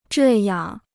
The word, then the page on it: 这样: zhè yàng